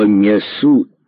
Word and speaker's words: мясу: Под картиной на мраморной плите высекли стих литератора: «Ты, подружка, меньше гони, не гугуй, не ку-ку, мы Америку догоним по мясу и молоку». Внизу мелкими буквами высекли примечание: в слове «мясу» ударение на втором слоге.